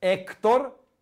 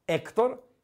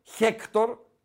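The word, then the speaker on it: Héctor: Έκτορ, Έκτορ, Χέκτορ, γράφεται. – Ναι, ναι. Χερέρα με δύο ρότα πρώτα, αλλά δεν διαβάζεται, είπαμε.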